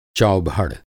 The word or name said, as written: चौभड़